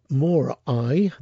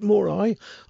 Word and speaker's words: Moirai: Now, Morai, that's M-O-I-R-A-I, but that's how you pronounce it, Morai, are Joe Freya, Melanie Briggs and Sarah Matthew.